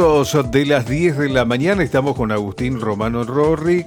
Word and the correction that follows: Norri: concejal capitalino. – Norri. Hoy estamos los dos. – Sí, bueno, pero lo dije bien, digamos. – Rorri dijo. – Como que metió una triple. – Rorri. Parece que nos hacía pollito con verdura ayer, Romano. – Agustín Romano Norri.